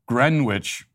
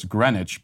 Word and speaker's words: Greenwich: Steve-O says, Matt's pronunciation of Greenwich as Greenwich bothered me at first. A man with Matt's intelligence should know it's Greenwich, but then I laughed knowing that Matt Walsh makes no mistakes.